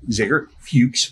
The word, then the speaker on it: Zager Fuchs: We have our Spinnaker Award winners here today for community service of Zager Fuchs. Did I say it right? – Got it. Nailed it.